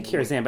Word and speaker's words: Karazhan: Last, Karazhan, I keep saying Karazhan, but I know it's Karazhan. – It rhymes with phenomenon.